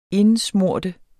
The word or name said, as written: indsmøre